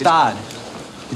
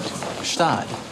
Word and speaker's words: Gstaad: The Christmas in Gstaad is going to cost us. – Gstaad. The G is silent. Gstaad.